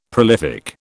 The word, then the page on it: prolific: British English